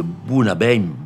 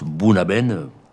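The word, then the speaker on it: Bunnahabhain: j'ai redécouvert donc le Bunabem, on l'écrit Bunahablen. Bon voilà, on prononce Bunabem, approché pour un chalossier.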